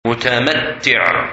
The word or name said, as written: Mutamatti